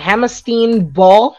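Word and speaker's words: Hammerstein Ballroom: I don't know how to pronounce it. Correct me if I'm wrong. Hammerstein Ball. Okay, cool. I got it right.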